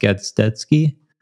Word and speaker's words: Gazdecki: I don't know my dad's side too well, but someone else told me it was Polish, but if you ask like a Polish person, they'll say it's pronounced Gazdecki.